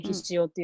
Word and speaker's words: 醤: ですよね、醤油の醤という字でひしおって読むんですけど、うん、その醤油っていうのは、醤油って言葉が出たのは室町時代なんですけど、それまではひしおっていう名前だったの。